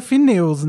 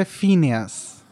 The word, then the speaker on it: Phineas: Em português é Phineus, né? Porque em inglês é Phineas.